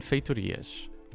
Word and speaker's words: feitoria: True or false, the largest buildings that can be built without the scenario editor are wonders and feitorias. By the way, that's how you pronounce feitoria.